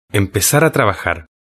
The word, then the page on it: empezar a trabajar: European Spanish